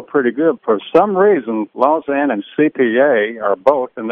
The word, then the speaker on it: Lausanne: Last year, Lausanne, no problem. I say that right, Stormy? – Lausanne. – I always want to say Lausanne.